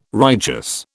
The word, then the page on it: righteous: British English